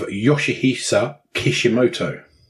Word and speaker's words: Yoshihisa Kishimoto: And we're going to Japan with a video game designer by the name of Yoshihisa Kishimoto. – Well pronounced, sir.